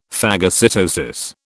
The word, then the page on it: phagocytosis: British English